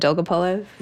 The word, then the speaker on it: Dolgopolov: And even when you talk about Alexander Dolgopolov, how do I pronounce that? Dolgopolov? – Mm-hmm.